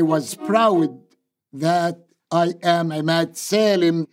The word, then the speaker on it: Emad Salem: He pronounces it Emad Salem. His former FBI handler calls him Emad Salem. And when I asked him about it, he told me to call him Emad Salem, because he said, that seems to be the easiest for non-Arabic speakers.